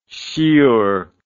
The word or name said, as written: shiur